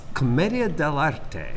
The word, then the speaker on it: commedia dell'arte: It's commedia dell'arte. – Oh, there you go. – Yeah, it's Italian, so you pronounce all the vowels.